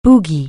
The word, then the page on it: boogie: anglais